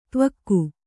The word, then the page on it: ದಿವೌಕ: divauka